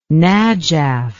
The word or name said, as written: najaf